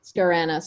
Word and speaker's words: Scorrano: Okay, I was gonna say give it a try, but it's Scorrano. – Scorrano.